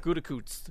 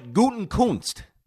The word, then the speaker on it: Gutekunst: Gutekunst.